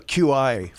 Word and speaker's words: Qi: It's pronounced Qi. – Yeah. – In case you didn't know. We'd like to inform our audience about that. So you don't have a Qi phone.